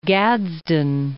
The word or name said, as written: Gadsden